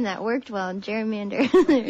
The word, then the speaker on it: gerrymander: And so they called it a gerrymander, but we've since changed the pronunciation over the years to gerrymander.